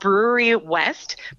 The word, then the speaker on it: Brouwerij West: Yes, it's called Brewery West, but it's spelled Brouwerij West.